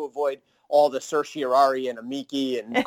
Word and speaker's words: amici: I've heard it both ways when I took Latin. – Was, I say amici. I say it like a hard key.